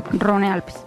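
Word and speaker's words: Rhône-Alpes: Eso es Averno Rino. Rino Alpes. – Rone Alpes. – O Rone Alpes, sí.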